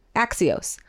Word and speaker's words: Axios: Axios.